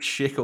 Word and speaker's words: Schick: We have Czech Republic striker Patrick Schick or Treat. Patrick Schick or Treat. – You see, ladies and gentlemen, because Schick rhymes with trick.